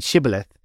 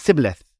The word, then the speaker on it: shibboleth: but he said shibboleth, and he kept pronouncing it sibboleth, for he wasn't of God's people.